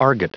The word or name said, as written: argot